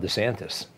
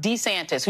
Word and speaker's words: DeSantis: It's either DeSantis or DeSantis, depending on whether his wife is telling him what it is. – Who knew the long versus short version of a vowel could cause all this political calamity?